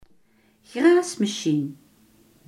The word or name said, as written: grasmaaier